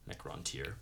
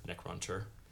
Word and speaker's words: Necrontyr: uh, it's Necron and then T Y R. Yeah. Necrontyr. Necron tier. Necron tier. Yeah.